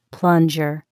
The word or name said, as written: plunger